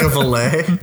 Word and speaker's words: valet: Like a valet? – Like a valet. That's something else too. – A valet. – A valet. Yeah. – Yeah. – Emphasis is on the first part.